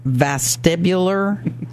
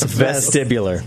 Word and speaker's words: vestibular: What does vestibular rehab mean? Did I even say it right? – You did. Very close. Vestibular. – Vestibular. – With an E.